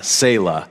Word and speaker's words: Selah: It's really not a great translation of the word in Hebrew, which is, I put it in your notes, it looks like Tesla misspelled, but it's, you say it, Selah is the way you pronounce the word.